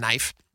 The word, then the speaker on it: Naif: His name his name is pronounced Knife.